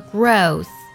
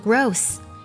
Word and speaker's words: growth: 接下来我们来看一下，在读这句话的时候呢，有一个单词完美的，它的重音呢是在 per 这个音节上，很多同学呢不小心读作 perfect，那么它的形容词呢我们是读作 perfect，还有成长这个单词读作 growth，注意词尾的 th 音呢我们要读作咬舌音，不要读作 growth，一定要注意把舌尖轻轻地放在上下齿之间，听起来才非常的正确和地道。